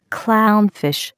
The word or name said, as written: clown fish